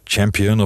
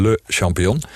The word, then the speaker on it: Le Champion: Is het champion of op zijn Nederlands? En dan, is het, dan zeg ik steeds: het is gewoon le champion.